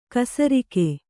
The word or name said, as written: ಖಾಸರೋಗ